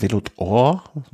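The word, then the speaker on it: Vélo d'Or: Ähm, Velodor, spricht man das so aus? Velodor? – Ja.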